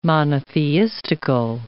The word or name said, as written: monotheistical